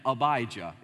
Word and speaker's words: Abijah: He was of the division of, now think about Elijah, and you'll be able to pronounce this name, Abijah.